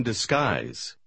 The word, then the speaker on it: disguise: В последнем слове первая S звучит как S, а вторая как Z. – She came in disguise.